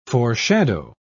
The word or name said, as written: foreshadow